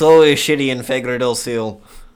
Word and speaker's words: Fagradalsfjall: Can you pronounce it? Fagradalsfjall. Fagradalsfjall. – Fagradalsfjall. – Fagradalsfjall. I think that's right on.